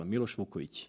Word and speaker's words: Milos Vukovic: Milos Vukovic.